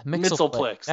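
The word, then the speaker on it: Mxyzptlk: Isn't that Mitzelplex? – Yeah, Mitzelplex. That's it.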